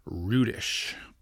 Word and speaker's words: Rudish: He knows him, you know, just as well as anybody else, but he knows that you pronounce his name Paul Rudish. So we have been saying it incorrectly.